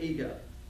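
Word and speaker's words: Ego (ἐγώ): And that word is, in the Greek, it's spelled E-G-O.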